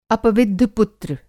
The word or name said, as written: अपविद्ध पुत्र